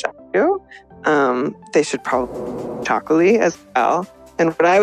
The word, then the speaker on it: txakoli: And Chocolaty is hard to pronounce. And I don't want people to be intimidated, but it's from the Basque region in Spain, and it's TXAKOLI. I hope I spelled that correctly. – Wouldn't have thought that there was an X in it. – I know, which I don't want people to be scared off by. It's pronounced Chocolaty. It's not too hard to say.